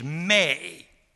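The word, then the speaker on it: may (μή): And the negative particle of the non-indicative moods is may. May, we would say, M with a long A.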